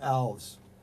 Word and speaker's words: Alves: Dalby versus Alves. I always pronounce Alves because around down New Bedford, Fall River, there's a lot of Portuguese. And I know a lot of Alves.